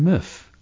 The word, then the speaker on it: meuf: So une femme, French for a woman, becomes une meuf, M-E-U-F. Not an exact reversal of the F-E-M-M-E, femme, but certainly sounds like it. So, what a strange girl might be, quelle meuf zarbi, instead of quelle femme bizarre, quelle meuf zarbi.